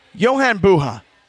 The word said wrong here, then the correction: Jovan Buha: Jovan. – Jovan. – Yeah. – He's never going to get this. – My man. – Yeah. J-O-V-A-N.